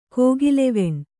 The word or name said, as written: ಕೋಗಿಲೆವೆಣ್